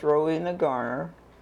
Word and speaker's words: Rowena Garner: My name is Rowena Garner, R-O-W-E-N-A-G-A-R-N-E-R, and I live at Sugar Grove, Logan County, Arkansas.